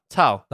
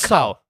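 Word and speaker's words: 操: And in Chinese, shiba it's tao. – Tao. – Tao. – That was good? – C-A-O. Tao. – C-A-O? – Tao. Not cow. Not cow.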